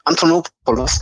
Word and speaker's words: Antonopoulos: Antonopoulos.